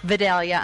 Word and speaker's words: Vidalia: It's an honor to get to try those Vidalia onions.